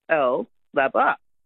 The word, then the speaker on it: Capalaba: Because that's how, if you look at it, it's C-A-P-A-L-B-A, Capalaba.